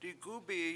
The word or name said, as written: digUbiyi